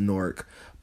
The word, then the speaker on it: Newark: It's pronounced Newark by people from Newark. It's spelled like Newark. We got one in Delaware, where the University of Delaware is, but in Jersey, they call it Newark.